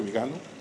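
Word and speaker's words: Viganò: Entonces decidí ir a buscar las pruebas, buscar las entrevistas del cardenal Vigano, que es como se pronuncia, Vigano.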